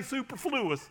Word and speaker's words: superfluous: That's superfluous. How do you say that word? what? Superfluous. That, that sounds so much prettier.